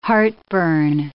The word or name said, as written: heartburn